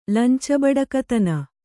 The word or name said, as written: ಲಂಚಬಡಕತನ